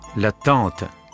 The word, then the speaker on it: tente: Да, слово звучит так же, только пишется по-другому. Палатка «la tente» пишется через «en».